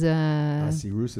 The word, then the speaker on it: Ahasuerus: Or how do you say it? I'm sure you're going to correct me. – We say Ahasuerus and... – Ahasuerus is Ahasuerus.